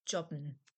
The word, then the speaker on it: jobben: Jobben, to gig, to work a short-term job. Hard J, English pronunciation.